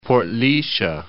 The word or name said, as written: Portlaoighise